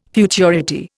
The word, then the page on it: futurity: Indian